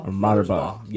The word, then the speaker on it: Mothersbaugh: Mark Mothersbaugh. Did I say that right? – Mothersbaugh.